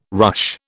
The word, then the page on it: ruche: British English